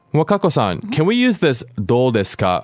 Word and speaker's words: どう: D-O with a long O, do.